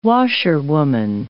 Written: washerwoman

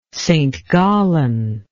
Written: St Gallen

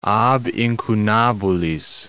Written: ab incunabulis

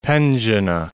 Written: Penzhina

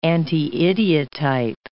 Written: anti-idiotype

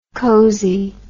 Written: cosie

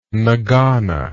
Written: ngana

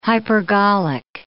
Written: hypergolic

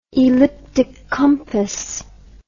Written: elliptic compass

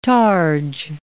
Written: targe

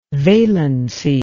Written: valency

